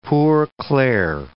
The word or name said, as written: Poor Clare